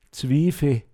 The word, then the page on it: zåmmschoa(r)gn: Pinzgauer Mundart